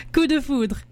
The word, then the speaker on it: Coup de Foudre: And Apolline is a local Vancouver artist with a new single out called Coup de Fondre. Did I say that right? – Coup de Foudre. – Coup de Foudre. – So close.